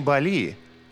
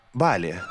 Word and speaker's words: Бали: А вообще-то правильно говорить не Бали, а Бали.